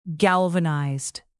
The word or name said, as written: galvanized